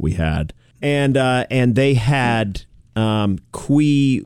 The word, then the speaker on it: cuy: Qui is the word they use. C-U-Y, qui.